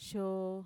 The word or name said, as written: xoo